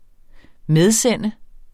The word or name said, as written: medsende